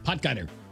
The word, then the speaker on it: Potgieter: Potgieter.